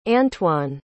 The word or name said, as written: antwon